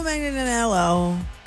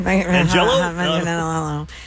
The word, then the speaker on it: Manganiello: All right, so Joe Manganiello. That's how you say his name. – Manganiello.